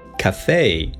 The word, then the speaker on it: café: 非常常见，咖啡馆（cafe）读成 cafe，生活中经常看到它。